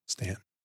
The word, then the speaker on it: Staunton: Going to have a nice, take you guys on a nice little fun twisty road out of Staunton, Virginia. And Stan? They pronounce it Stan. – All right. – I was corrected when I stopped at a hotel once.